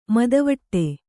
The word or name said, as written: ಮದವಟ್ಟೆ